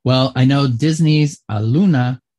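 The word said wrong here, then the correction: Aulani: am I pronouncing that? Alani.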